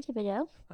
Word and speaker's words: Thibodeaux: Okay, so my guest is Andre Thibodeaux.